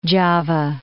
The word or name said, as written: Djawa